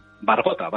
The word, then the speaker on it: Bargota: es el brujo de Bargota o Bargota, ¿cómo se dice? – Bargota, Bargota. – Bargota. Eso es, Bargota, eso es.